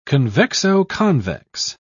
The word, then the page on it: convexo-convex: British English